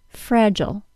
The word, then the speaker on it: fragile: Today we're going to talk about a pattern that explains American and British pronunciation of multisyllable words that end in I-L-E. The word F-R-A-G-I-L-E is pronounced fragile, ending in schwa plus L sound in the U.S., and fragile, ending in long I plus L sound in the U.K.